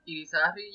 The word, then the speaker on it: Irizarry: Irizarry, yeah. – Nice. – I always say eerie like eerie and sorry like sorry with an S.